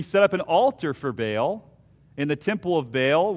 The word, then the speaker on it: Baal: now I say Baal, not Baal, however you want to pronounce it, and worshipped him.